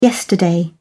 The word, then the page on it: yesterday: British English